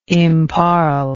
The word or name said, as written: imparl